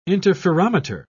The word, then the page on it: interferometer: British English